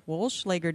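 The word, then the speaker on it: Wollschlaeger: It's the, I'm going to butcher this name, Wolschlager. How'd I do? Wolschlager decision from the 11th Circuit. How'd I do? – Yeah, I think you did great.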